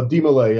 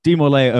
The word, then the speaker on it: DeMolay: You mentioned, um, I think it's uh, if I'm pronouncing it right, the the De Society. – Uh, Demolay, yeah. – Demolay, okay, because yeah, I I grew up.